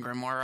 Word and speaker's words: grimoire: Am I pronouncing grimoire right? – You're pronouncing it right, but I don't even know exactly what that is.